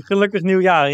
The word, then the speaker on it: Gelukkig Nieuwjaar: Gelukkig Nieuwjaar. Is that how you pronounce it? – Yeah, exactly. Gelukkig Nieuwjaar, yeah.